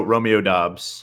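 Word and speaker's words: Romeo Doubs: How do you pronounce his last name? – Romeo Dobbs.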